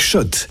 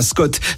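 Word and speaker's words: Schott: Jouer et gagner sur RMC avec Shot, la marque iconique new-yorkaise depuis 1913. Oui, Shot. Et pas Scott, ça fait des années qu'on prononce mal la marque.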